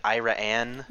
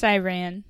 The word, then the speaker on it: Iran: And is that how it's pronounced? It's not like Ira-Ann? – No, it's pronounced Iran.